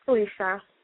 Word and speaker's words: Felicia: No, it's Felicia. – How do you spell that? – F-E-L-I-C-I-A.